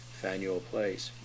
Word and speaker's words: Faneuil: Exactly. F-A-N-E-U-I-L, Faneuil Place.